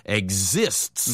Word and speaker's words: exists: E-X-I-S-T. E-X-I-S-T. 다음에 3인칭이니까 S 붙는 거고요. – Yeah, your pronunciation is really important here because we're not really saying the word exit. That's different. This is exists.